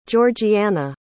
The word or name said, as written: georgianna